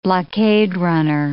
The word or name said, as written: blockade-runner